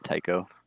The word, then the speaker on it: Tycho: Was it Ticho or Tycho? – I would pronounce that Tycho.